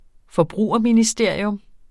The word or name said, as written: forbrugerministerium